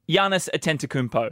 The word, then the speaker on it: Giannis Antetokounmpo: Giannis Antetokounmpo is how, but that's...